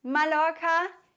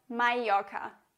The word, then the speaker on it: Mallorca: Mallorca ist auf jeden Fall falsch ausgesprochen. Es heißt Mallorca. Doppel-L, immer ein J.